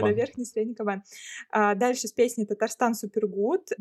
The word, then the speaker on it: Кабан: А дальше Казанская набережная Кабана я могу неправильно ударение ставить, но вроде как кабана, кабана да. все правильно.